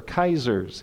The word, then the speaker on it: Caesar: And they said Caesar's, or Kaiser's, if you're, which is the literal pronunciation of it, and those with German background remember that pronunciation.